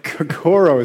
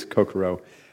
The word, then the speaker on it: Kokoro: Kokoro is the way I pronounce it, but it actually is Kokoro. It's a Japanese word, if you hadn't figured that out, and it means heart mind or whole mind.